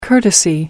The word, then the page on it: courtesy: American English